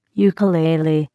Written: ukulele

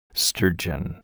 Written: sturgeon

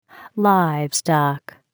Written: livestock

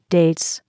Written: dates

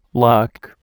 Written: lock

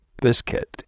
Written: biscuit

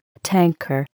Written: tanker

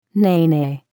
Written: nene

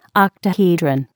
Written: octahedron

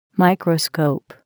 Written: microscope

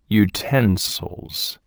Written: utensils

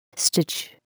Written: stitch

